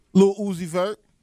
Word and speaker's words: Lil Uzi Vert: Lil Uzi Vert.